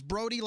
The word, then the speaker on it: Brody: It's Brody, like the homeland, in case you don't know the pronunciation.